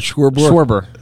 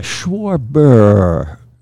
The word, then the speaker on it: Schwarber: Not that elongated. – Yes. – Okay, Schwarber. – There you go. You got it. – Say sure, and then just call it Schwarber, like a little bit. Like now I'm saying it wrong. – S-U-R-E-B-E-R? – Like a bird on a beach.